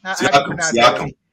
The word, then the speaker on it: Siakam: We're going to talk Pascal – how do you say that? Siakam or Siakam? – Siakam. – Siakam. – Pascal Siakam.